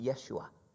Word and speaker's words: Yeshua: Jesus, in Hebrew, the name is pronounced Yeshua.